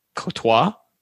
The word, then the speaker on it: Comtois: The most interesting thing about the Ducks apparently is that someone in the chat said, Comtois, apparently the M is silent. So I don't even know how to pronounce it now. Comtois?